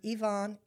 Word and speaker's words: Yvonne: And I knew I could remember it because I used to tell people, my name is Yvonne, it rhymes with Avon, but I'm not the ding-dong lady.